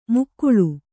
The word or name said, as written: ಮಗುಳು